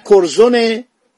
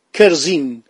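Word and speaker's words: Curzon: کورزون در جلد دوم کتاب خود اخیرا انگلیسی ها زبانشناسانشون گفتن این اسم ایرانی ها اشتباهی تلفظ میکنن نه کرزونه نه کرزن این اسم کرزین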